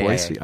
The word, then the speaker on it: Boise: I did say it right, though, right? – Yeah. – Boise. – I Googled that, too. Very good. The S. you pass.